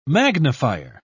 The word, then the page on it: magnifier: British English